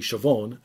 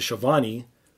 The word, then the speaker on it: Schiavone: And did you know that it's not Schiavone, it's actually Chavone, and Tony changed it to Schiavone because it rolls off easier than Chavone.